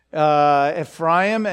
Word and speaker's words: Ephraim: Because the tribe of Joseph was divided up between his two sons, Ephraim and Manasseh, which is how you pronounce those names. Ephraim and Manasseh, these two sons.